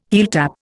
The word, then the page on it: heeltap: Indian